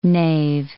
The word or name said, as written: knave